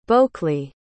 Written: bocley

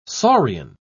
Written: saurian

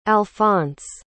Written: alphons

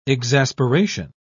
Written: exasperation

